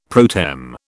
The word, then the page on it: pro tem: British English